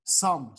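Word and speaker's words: Xumm: Do you use the Zoom wallet? Is that how you pronounce it? Zoom? – Sum. Like with an S. Sum. Just like Sum.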